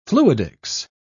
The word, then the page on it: fluidics: British English